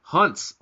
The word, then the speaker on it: Hans: It is actually pronounced Hunts, like the ketchup. Holy crap, not Hans like Hans Moleman.